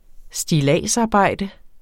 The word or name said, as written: stilladsarbejde